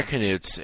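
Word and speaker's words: Knuts: I actually think they're pronounced newts. Like, really? – Yeah, actually. – Yeah, they're canutes. If you look on the Scholastic pronunciation. – Canutes or newts? – Canutes. – Oh, canutes.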